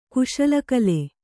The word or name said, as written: ಕುಶಲಕಲೆ